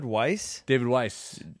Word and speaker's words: Weiss: David Weiss. That's how you pronounce Weiss.